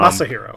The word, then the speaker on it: Masahiro: Mashiro Sakurai. – Um, Masahiro.